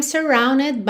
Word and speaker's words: surrounded: Everywhere I'm looking now, I'm surrounded by your embrace. Essa palavra aqui, surrounded, a gente pronuncia esse E.